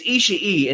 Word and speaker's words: Ishii: Josh Barnett did too, but not nearly as much because there were things like Josh, like Jim Ross was arguing the correct pronunciation of Tomohiro Ishii's name. It's Ishii, and he tried to argue that it wasn't.